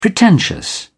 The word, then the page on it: pretentious: British English